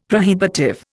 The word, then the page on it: prohibitive: Indian